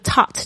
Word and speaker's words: taught: 所以这个 taught 它就是 teach T-E-A-C-H 的过去式 T-A-U-G-H-T, 注意一下 G-H 并没有发音哦。